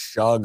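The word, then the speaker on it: Shough: Yeah, his name looks like Slow, but it's pronounced Shug or whatever it is.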